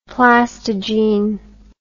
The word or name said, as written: plastogene